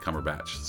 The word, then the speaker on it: Cumberbatch: I feel like almost I'm cheating because I'm already a fan of Martin Freeman and Benedict Cumberbatch.